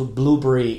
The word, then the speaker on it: Blubrry: And you can just go to Blueberry. It's spelled B-L-U-B-R-R-Y .com.